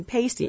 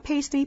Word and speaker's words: pasty: Pasty, pasty, pasty. No, not pasty. Pasty.